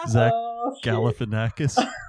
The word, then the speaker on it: Zach Galifianakis: Zach Galifianakis.